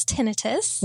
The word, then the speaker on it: tinnitus: So we in the audiology community pronounce it as tinnitus.